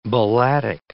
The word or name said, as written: balladic